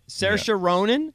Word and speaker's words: Saoirse: You, if you want to look her up, Cerise Ronan. Is that her name? – Saoirse. – Is that how you say it? Saoirse Ronan.